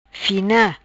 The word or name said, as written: Fina